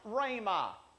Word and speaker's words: rhema: Not only is there logos, but there's also the Greek word that's, that's, that's pronounced rhema.